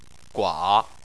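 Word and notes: gua3: tone 3

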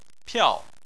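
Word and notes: piao4: tone 4

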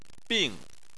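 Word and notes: bing: tone 4

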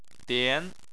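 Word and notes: dian: tone 2

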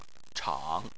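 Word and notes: chang3: tone 3

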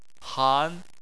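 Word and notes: han: tone 2

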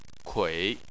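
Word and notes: kui: tone 3

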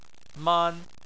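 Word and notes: man: tone 1

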